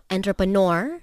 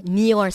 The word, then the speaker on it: entrepreneur: I'm so happy kerana ramai orang uh, salah sebut perkataan entrepreneur. Yeah. Ada yang sebut entrepreneur. – Uh-huh. Hmm. – Entrepreneur. neor sebab dia ikut ejaan N-E-U-R. Salah. Uh, sebutan yang sebetulnya adalah seperti mana Haiza sebut tadi. Entrepreneur. Entrepreneur.